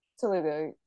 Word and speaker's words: Telugu: Now, I didn't want to list the lyricists because the original film is in, how do you say, Telugu? – Telugu, I believe that's how you say it.